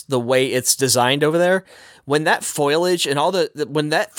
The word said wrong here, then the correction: foliage: forbidden forest foliage. – What did I say? – You put the wrong emphasis on the wrong syllable.